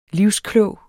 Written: livsklog

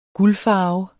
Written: guldfarve

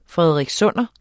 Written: frederikssunder

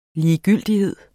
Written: ligegyldighed

